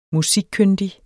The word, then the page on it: musikkyndig: [ -ˌkønˀdi ]; [ -ˌkøndi ]